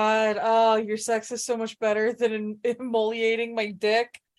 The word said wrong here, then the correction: immolating: I'm saying that right, right? immolating.